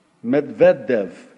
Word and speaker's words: Medvedev: Number five, the Russian government of Dmitry, bear with me on the pronunciation of this guy's last name, Medvedev, yeah, there we go.